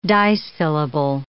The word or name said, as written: dissyllable